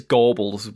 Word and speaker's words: Gorbals: in somewhere like the Gorbals, is that, am I saying it right? – It's Gorbals with a G. – Like Gorbals, like the SS officer? – No. Oh God, no, that'd be terrible. Gorbals, like Gorbals. – Okay, Gorbals, got it.